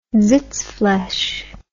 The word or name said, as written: zitsflaish